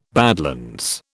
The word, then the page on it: badlands: British English